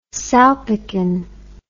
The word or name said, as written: salpicon